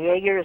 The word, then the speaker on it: Yaeger: And Yaeger is spelled Y-A-E-G-E-R.